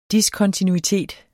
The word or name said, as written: diskontinuitet